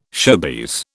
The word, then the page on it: showbiz: British English